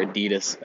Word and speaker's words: Adidas: I mean, like, if you think about brands, the first thing that I think of is Adidas or Adidas, as the North Americans say, like Nike, like sports, like clothing, that kind of thing.